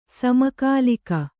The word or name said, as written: ಸಮಕಾಲಿಕ